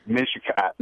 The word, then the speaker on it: Mishicot: Is it Mishiot? Mishicot? – Mishicot.